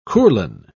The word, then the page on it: courlan: British English